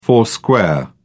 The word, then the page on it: four square: British English